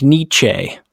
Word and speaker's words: Nietzsche: Is that how you say it? Nietzsche? – Sure. Yeah. – Friedrich Nietzsche.